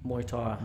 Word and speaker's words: Muay Thai: Muay Thai.